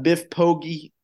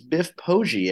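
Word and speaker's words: Biff Poggi: Biff Pogge. I got his name wrong. It's Biff Pogge, everybody. 17-point deficit, came back and beat Tulsa in overtime.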